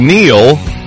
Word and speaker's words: Neil: It's no surprise Neil rhymes with deal, because that's exactly what you get from the name drivers have trusted for over a century.